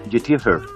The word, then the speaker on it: YouTuber: Que, que, o sea, para empezar se dice youtuber.